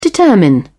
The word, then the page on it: determine: British English